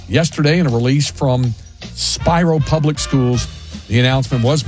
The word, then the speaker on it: Spiro: The town of Spiro, Oklahoma, spelled like Spiro, S-P-I-R-O, but it's pronounced Spiro.